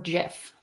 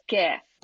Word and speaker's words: GIF: Do you pronounce it GIF or JIF? – I learned this yesterday. It's GIF. – Right answer.